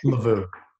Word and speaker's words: Lovoo: And Lovoo, is that how you pronounce that one? – Lovoo.